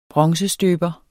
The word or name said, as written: bronzestøber